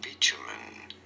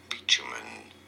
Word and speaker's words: bitumen: There is no T sound here, but rather a ch sound. Bitumen. – Bitumen. – You don't want to stress on the first syllable.